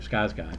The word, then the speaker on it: Skarsgård: No, like you were saying about Tim Curry, I think he did a great job, but I think what Bill Skarsgård, I believe that's how you pronounce his name, Skarsgård.